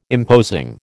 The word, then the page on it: imposing: American English